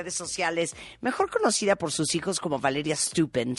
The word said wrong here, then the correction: Stoppen: Stoppen, Stoppen. Siempre la discusión de cómo se pronuncia tu apellido, hija. – Stoppen, mamá, Stoppen. – Stoppen, Stoppen.